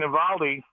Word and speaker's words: Eovaldi: I think they made the right choice by putting Nathan Eovaldi. I believe that's how you pronounce that last name. – That's right.